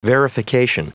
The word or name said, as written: verification